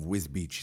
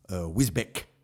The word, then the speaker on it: Wisbech: And when you look at the name of Wisbeach, it's one of them, you know it's pronounced Wisbeach, but it's actually spelled Wisbeck, Wisbeck, W-I-S-B-E-C-H.